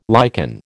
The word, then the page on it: liken: American English